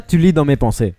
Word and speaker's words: Tu lis dans mes pensées: Quita, es que la S en Francia casi nunca se pronuncia, así que pero ahora tiene, está perfecto. – Tiene 200 S. Pues no se dice ninguna. – Se pronuncia tu lis dans mes pensées.